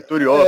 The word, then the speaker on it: Turiaf: pronúncia é, foi essa que você fez aí mesmo. Eu acho que você aprendeu. – Rony Turió.